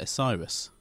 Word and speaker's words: Osiris: Osiris? – Uh-huh.